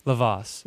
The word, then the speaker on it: Lovass: Did I say that right? – Kind of, yeah. It's better than Lovass. – How do you say it? – Lovass. – Lovass. Yeah. Oh, okay. – Like you love Voss water.